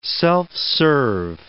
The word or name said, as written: self-serve